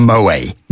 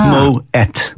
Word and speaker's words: Moët: Actually, I think one of the first things I ever learned on this lot was that it wasn't called Moët. – Because a lot of people still probably call it that. You do pronounce the T. – Yeah, and well, there's the little two dots over the E, so you would that makes a two syllable word. So it's Moët.